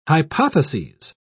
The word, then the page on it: hypotheses: British English